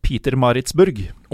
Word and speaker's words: Pietermaritzburg: Det er eh, selve Savages FC Peter Maritzburg.